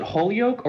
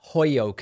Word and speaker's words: Holyoke: Okay, did you pronounce it Holyoke or Hoyoke? – No. I'm not into this whole Hoyoke thing. I pronounce it Holyoke.